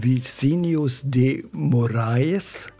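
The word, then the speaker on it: Vinícius de Moraes: Die zwei Komponisten, Antonio Carlos Jobim und Vicinius de Moraes, ich hoffe, ich habe das richtig ausgesprochen. – Ich sage ja.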